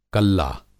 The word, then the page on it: कल्ला: کلا